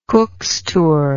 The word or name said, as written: cookstour